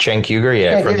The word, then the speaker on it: Cenk Uygur: He called Cenk Uygur up there. Is that how you say his name? – Cenk Uygur, the Young Turks.